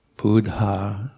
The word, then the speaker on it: Buddha: And then also very tricky for English speakers is try to pronounce both letters of a double consonant. So Buddha, Sabha, Satta. So I try to sneak in a little sound between a double consonant, like Buddha, Sabha, Satanta, but it never quite comes out right.